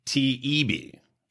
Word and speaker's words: Taibbi: Taibbi. – Taibbi.